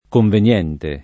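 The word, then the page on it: conveniente: italiano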